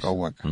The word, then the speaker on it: Guacha: Waka. ¿Mm? Sí, se escribe Guacha. – Sí. – Se pronuncia Waka.